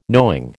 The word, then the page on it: knowing: American English